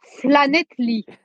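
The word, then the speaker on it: Llanelli: It's like me, you know, trying to pronounce Flanetly. Is that correct? – Good. That's not bad.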